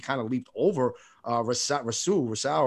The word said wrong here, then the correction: Rousseau: or whatever, however you pronounce his name. – I think Gregory Rousseau. Yeah, Rousseau,